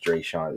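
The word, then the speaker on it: Dreasjon: Well, I think it's pronounced Dre Sean. It's D R E A S J O N Reed.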